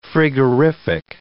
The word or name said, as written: frigorific